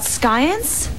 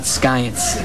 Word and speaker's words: science: I still have to research Madame Curie, but I don't know anything about science. – Kel, that's science, not skyance. The sea is silent.